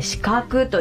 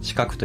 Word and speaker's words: 鹿の角: はい、オンラインショップで販売されている家具屋さんということですけれども、鹿の角と書いて資格と読むお名前だそうなんですけれども、こちらの由来、意味っていうのはどういったところにありますか。 – はい、ブランド名は漢字で鹿の角と書いて資格と読むのですが、